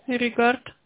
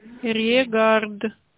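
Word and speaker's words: regard: Регард. Как слышится, так и пишется, да, на конце. .pro. – Иригард, да? – Регард. – А, регард. – Регард.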